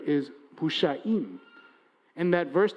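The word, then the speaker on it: busha'im (מושעים): With one set of vowels, the pronunciation, the combination of this word is it is busha'im, those who have been saved.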